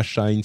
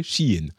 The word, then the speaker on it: Shein: Et évidemment, bon, Shein, c'est pas Shine, c'est pas c'est, toutes les prononciations, c'est Shein en fait.